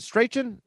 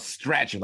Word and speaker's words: Strachan: Hilton, Pittman, Pascal, Parris Campbell, Dezmon Patmon, uh, Michael Stra- uh, is it Strachan? Is, it, is that how you pronounce it? Strachan. – I thought it was Strachan, like scratching for him, scratching.